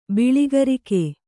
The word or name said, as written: ಬೆಳ್ಳಕ್ಕರಿಗ